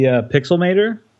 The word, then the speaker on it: Pixelmator: I guess my favorite app lately would be Pixelmator. Is that how you say it? – I think so.